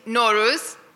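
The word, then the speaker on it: Nowruz: It's Noruz.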